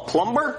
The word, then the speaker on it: plumber: Hey there, somebody call a plumber? – A what? – A plumber. – Do you mean a plumber? – That's what I'm saying, plumber. It has a B in it. – The B is silent.